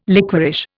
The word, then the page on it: liquorish: Indian